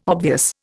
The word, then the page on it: obvious: Indian